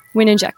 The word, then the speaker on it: Wininger: Wininger, cool.